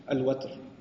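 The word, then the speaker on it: وتر: Which is why we find in the hadith, فَإِنَّ اللَّهَ تَعَالَى وَاحِدٌ وَتْرٌ, or witr also. Watr is also pronounced witr, but it's also, it's argued in Nisan al-Arab that the better pronunciation is al-watr.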